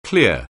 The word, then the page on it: clear: anglais